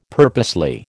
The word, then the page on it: purposely: American English